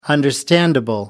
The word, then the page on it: understandable: American English